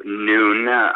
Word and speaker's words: Nuun: So I actually came to Noon, spelled N-U-U-N, for those wondering how you spell it, not O-O, as in midday, but sounds the same.